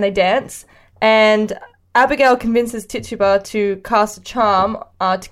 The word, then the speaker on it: Tituba: Tituba, who is from Barbados.